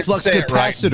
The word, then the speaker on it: Flux Capacitor: Oh, Flux Capacitator. Ho ho! Suck my cock! – That's not how you say it. It's the Flux Capacitor.